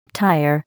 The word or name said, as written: tire